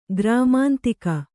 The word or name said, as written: ಗ್ರಾಮಾಂತಿಕ